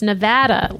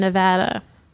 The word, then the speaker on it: Nevada: We have one last email from Sue, who writes, "It's Nevada. Like I had a blast in Nevada." – What? – Not Nevada. Like open up and say, ah.